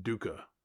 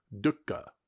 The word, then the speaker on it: dukkha: But it's actually not pronounced dukkha. It's properly pronounced, if I'm properly pronouncing it, it's properly pronounced as dukkha. Like a little glottal stop in the middle of it, dukkha.